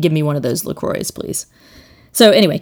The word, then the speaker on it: LaCroix: And it is LaCroix. The website says, you know, damn the French. It's not the French pronunciation. It rhymes with boy.